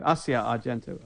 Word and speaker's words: Asia Argento: And she is the mother of Asia Argento, Asia Argento, I believe is how she pronounces her name, yeah.